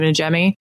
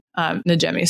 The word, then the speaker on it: Najimy: Is it Kathy Najimy or how do you say her last name? Najimy. – I forget her name. Yeah. – Najimy.